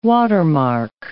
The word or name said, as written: watermark